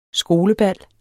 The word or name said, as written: skolebal